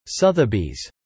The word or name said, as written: Sothebys